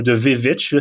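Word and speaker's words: The VVitch: Et euh, j'ai pas été surpris du tout de voir que c'est lui aussi qui a fait The Lighthouse et The Witch. Euh, ou The Vivitch, si vous voulez être euh, pointilleux sur la, prononciation puis l'écriture du titre.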